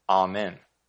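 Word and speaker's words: Amen (אָמֵן): And although the NKJV renders the Hebrew word as truth, the Hebrew word is actually pronounced Amen.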